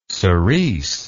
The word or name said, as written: siris